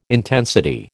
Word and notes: intensity: American English

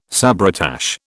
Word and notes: sabretache: British English

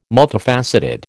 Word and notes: multifaceted: American English

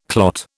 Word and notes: clot: British English

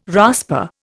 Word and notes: rasper: Indian

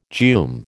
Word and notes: geum: American English

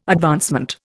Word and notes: advancement: Indian

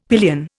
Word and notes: pillion: Indian

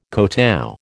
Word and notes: kotow: American English

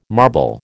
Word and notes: marble: American English

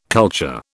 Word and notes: culture: British English

